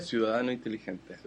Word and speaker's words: Ciudadano Inteligente: And he is the founder and director of, correct me if I mispronounce this, Fundación Ciudadano Inteligente. – Ciudadano Inteligente. – Yes, that.